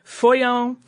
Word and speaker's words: Foillan: One was F-O-L-L-I-A-N, like a normal Follian pronunciation, and the other was F-O-I-L-L-A-N, which would be more like Foyon.